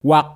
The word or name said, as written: Wak